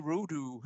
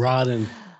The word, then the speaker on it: Rodin: Or if you were announcing this at the post position draw, it would be Auguste Rodin or something. I'm not sure how you would pronounce Rodin.